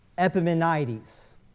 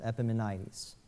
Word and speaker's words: Epimenides: His name is Epimenides, I believe is how it's pronounced. Epimenides.